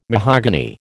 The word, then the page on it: mahogany: American English